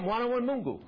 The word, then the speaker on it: Mwana wa Mungu: I said, did I say it right? Mwana wa Mungu? They said, yeah, Mwana wa Mungu.